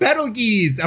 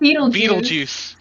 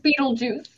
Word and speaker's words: Betelgeuse: We've got Betelgeuse. Am I saying that right? – Nope. Beetlejuice. – It's Beetlejuice.